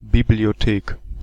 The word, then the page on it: bibliothèque: [bi.bli.jɔ.tɛk]; France